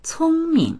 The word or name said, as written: cong1ming